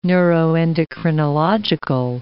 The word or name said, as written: neuroendocrinological